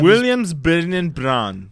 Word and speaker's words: Williams Birnenbrand: How do you pronounce this? Williams? – God damn. – Williams Birnenbrand.